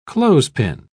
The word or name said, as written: clothespin